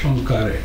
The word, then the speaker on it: Poincaré: Poincaré? In French? – Poincaré.